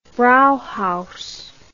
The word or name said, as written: brauhaus